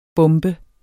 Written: bombe

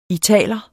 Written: italer

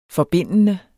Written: forbindende

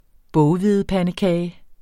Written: boghvedepandekage

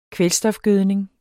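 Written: kvælstofgødning

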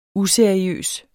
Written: useriøs

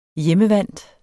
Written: hjemmevant

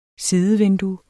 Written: sidevindue

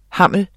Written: hammel

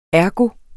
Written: ergo

